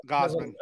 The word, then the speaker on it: Gausman: Is it Gausman? Is that how you pronounce it? – Yeah, Gausman. – I want to call him Guzman. I got a buddy named Guzman.